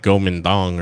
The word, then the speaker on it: Kuomintang: I've always heard it like as like Kuomintang, right? – Oh. – But it's like actually like Kuomintang, right? – Yeah, well, you move about...